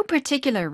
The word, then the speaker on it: particular: There is no particular reason. – 자, 이 particular 발음이 어려운데 T의 강세가 가다 보니까 앞에가 파가 아니라 푸로 약화시켜서 particular.